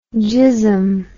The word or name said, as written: gizzum